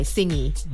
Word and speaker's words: Tsingy: So Singi is spelled T-S-I-N-G-Y. Singi.